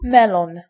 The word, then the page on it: melon: ['mɜ.lɔn]